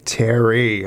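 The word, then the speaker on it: Terri: Terry.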